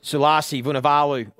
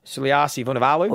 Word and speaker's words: Sulasi Vunivalu: More impressive is your pronunciation. – Thank you. – That was good. – We'll add it again. – Sulasi Vunivalu. – Oh, I like it. – You've just nailed that.